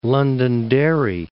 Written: Londonderry

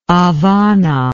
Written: habana

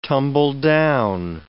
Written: tumbledown

